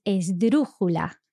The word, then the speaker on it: esdrújula: Yes, which is esdrújula. – It's a crazy word. Esdrújula. And really interestingly, this word is an esdrújula itself.